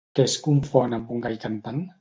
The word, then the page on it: confon: Catalan